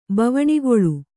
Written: ಬವಣಿಗೊಳು

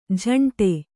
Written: ಜೊಂಡು

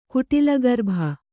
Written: ಕುಟಿಲಗರ್ಭ

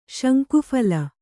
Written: ಶಂಕುಫಲ

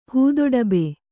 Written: ಹೂದೊಡಬೆ

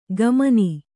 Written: ಕಮನಿ

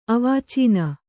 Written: ಅವಾಚೀನ

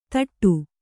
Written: ಥೋಡೆ

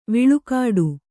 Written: ವಿಳ್ಕಾಡು

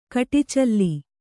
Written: ಕಡಚ್ಚಿಲು

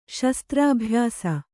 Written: ಶಸ್ತ್ರಾಭ್ಯಾಸ

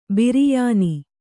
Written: ಬಿರಿಯಾನಿ

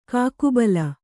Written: ಕಾಕುಬಲ

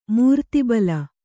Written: ಮೂರ್ತಿಬಲ

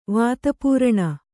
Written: ವಾತಪೂರಣ

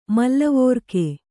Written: ಮೂಲಿವರ್ಗ

